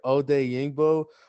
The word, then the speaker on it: Odeyingbo: Odeyingbo.